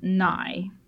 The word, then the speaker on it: Nye: Nye. N-Y-E, if that's how that's pronounced. – Like Bill Nye, the science guy.